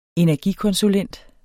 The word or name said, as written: energikonsulent